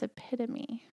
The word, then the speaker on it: epitome: But yeah, it's epitome. – Yes. – E-P-I-T-O-M-E. – Epitome. – Epitome. – Yep. – Yeah. And if you break it down in the syllables, it's epitome.